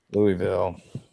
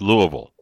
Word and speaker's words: Louisville: Louisville. – That's Louisville, not Louisville.